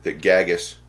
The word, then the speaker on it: GAGAS: It's one of the things that GAGAS, I always pronounce it with the GAG, so you get it on the front end because that's what you do when you read it.